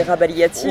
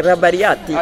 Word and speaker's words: Rabagliati: Pour les Français, c'est Rabagliati. Oh, hein. Okay. Pour les Italiens, c'est Rabagliati.